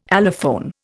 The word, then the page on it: allophone: Indian